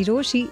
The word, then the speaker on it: Rochie: मेरा नाम है मिर्ची रोशी, नाम की स्पेलिंग है RochiE।